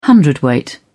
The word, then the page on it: hundredweight: British English